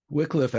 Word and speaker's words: Wycliffe: So first question I have for you is, how is small town clergyman preacher Evan Wycliffe? Did I say that correctly? Wycliffe, actually.